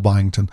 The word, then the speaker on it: Byington: That's also my Instagram and Twitter is Will Byington. I've been B-Y, spell it. – B-Y-I-N-G-T-O-N.